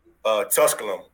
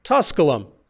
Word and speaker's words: Tusculum: Tusculum. – Tusculum.